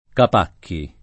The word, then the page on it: Capacchi: lingua italiana